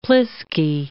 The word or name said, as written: pliskie